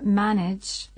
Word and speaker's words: manage: Pero fíjense que en la palabra manage, escrita M-A-N-A-G-E, la segunda A no suena como la A de map, sino que se pronuncia con un sonido parecido a la A-I. Y así decimos manage.